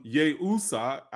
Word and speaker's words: Iusa: But if you trace it etymologically, it comes from the Kemetic term Yehusa, I-U-S-A, but the I is pronounced like a Y, okay?